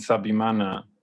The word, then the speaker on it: Nsabimana: So thank you, Jean-Claude and Nsabimana.